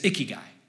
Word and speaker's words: ikigai: But how you say it is ikigai.